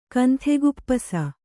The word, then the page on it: ಕಂಥೆಗುಪ್ಪಸ: Kannada